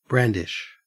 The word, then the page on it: brandish: Australian English